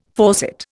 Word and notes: faucet: Indian

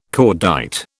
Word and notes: cordite: British English

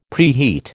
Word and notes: preheat: American English

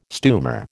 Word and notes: stumer: American English

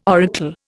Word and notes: oracle: Indian

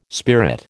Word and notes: spirit: American English

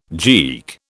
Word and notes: geek: British English

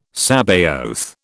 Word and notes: Sabaoth: British English

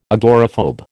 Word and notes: agoraphobe: American English

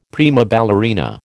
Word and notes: prima ballerina: American English

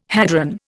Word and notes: hadron: Indian

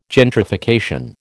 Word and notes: gentrification: American English

